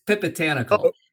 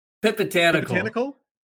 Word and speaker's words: Phipatanakul: It's not close, but I don't. It's Phipatanakul. – One more time. – Phipatanakul.